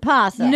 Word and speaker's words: opossum: It's called an opossum. Oh. – But you pronounce it possum. – No. – No, it's pronounced possum. Some people spell it with an O, some people... – It's pronounced possum, but there's an O in front of it.